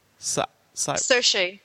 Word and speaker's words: Saoirse: How do you pronounce Cyrus Ronan? – Saoirse.